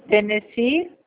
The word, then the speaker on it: Tennessee: Tennessee.